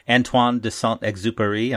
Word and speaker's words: Saint-Exupéry: Now, the book you chose is one of your favorite kids' books, is The Little Prince, written by Antoine de Saint-Exupéry.